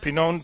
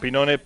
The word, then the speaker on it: Pinone: Claro, John Pinon pertenece, o John Pinone en nuestra pronunciación. – Es para inglés, sí.